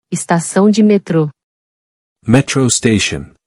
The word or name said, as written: Estacao de metro